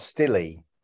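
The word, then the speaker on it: Stille: I mean, I haven't seen this, but is it not Stilly? – Yes, you nailed it.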